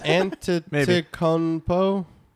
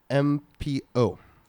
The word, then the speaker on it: Antetokounmpo: Antetokounmpo. – Great job. Great pronunciation. You are a Laker man. I can tell. – You ready? This is his last name. Antetokounmpo.